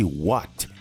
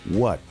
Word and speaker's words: what: E olha a pronúncia disso, tá? Esse what, ele várias vezes não vai ser falado tão aberto assim. Vai ser mais fechado. Então, what. What. Ou então, sem esse T também. What. What.